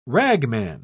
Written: ragman

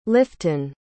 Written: lifton

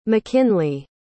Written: mckinley

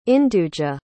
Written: induja

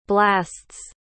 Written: blasts

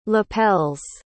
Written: lapels

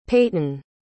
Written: payton

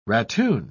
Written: ratoon

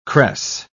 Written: cress

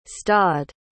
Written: stodd

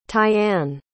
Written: tiane

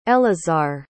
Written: elazar

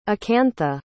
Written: akantha

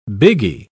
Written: biggie

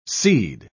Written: seed

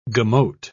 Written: gemot

